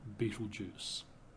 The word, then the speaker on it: Betelgeuse: But the pronunciation of that has been so bad over the last several hundred years, it's now devolved, if you like, to the name Betelgeuse.